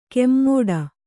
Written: ಕೆಮ್ಮೋಡ

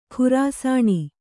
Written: ಖರಸಾಣೆ

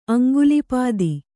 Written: ಅಂಗುಲಿಪಾದಿ

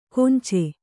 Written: ಕೊಂಚೆ

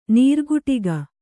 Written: ನೀರುಕುಟಿಗ

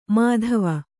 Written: ಮಾಧವ

